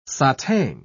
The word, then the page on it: satang: British English